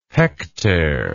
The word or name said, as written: hektare